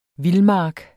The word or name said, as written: vildmark